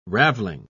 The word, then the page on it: raveling: British English